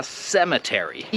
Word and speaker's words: cemetery: To słowo się wymawia cemetery. I tak naprawdę nie jestem pewny, czy to jest sem-e, czy sem-o. Może to jest coś między e i o, cemetery.